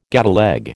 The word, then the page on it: gateleg: American English